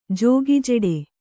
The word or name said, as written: ಜಗಜೆಟ್ಟಿ